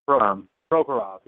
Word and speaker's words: Prokhorov: You know, the people don't really care to play for, what's his name, Prokhorov or whatever, however you pronounce it. You know? – Prokhorov. – Yes.